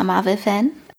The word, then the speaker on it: Marvel: Ich war meine Zeit lang ganz, ganz großer Marvel-Fan. – Ja. – Und dann nicht mehr. – Betonung auf wahr, okay. – Auf wahr.